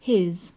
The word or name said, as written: his